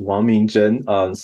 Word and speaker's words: Wang Mingzhen: Yeah, so Ming Chen Wang, or Chinese pronunciation is Wang Mingzhen.